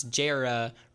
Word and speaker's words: Jara: And then we found out that it's Jara rhymes with Sarah, like the word chair.